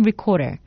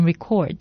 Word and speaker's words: record: 철자는 같지만 발음은 틀리죠. 기록을 뜻할 땐 record, 녹음하다 라는 뜻으로 쓰일 때는 record. 발음 주의하시기 바랍니다.